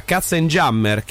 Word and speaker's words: Katzenjammer: parola tedesca che si dovrebbe pronunciare, e mi scuso con tutti i tedeschi all'ascolto, Katzenjammer,